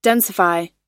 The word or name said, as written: densify